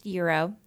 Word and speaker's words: gyro: I know that it is pronounced gyro.